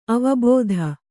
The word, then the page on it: ಅವಬೋಧೆ: avabōdhe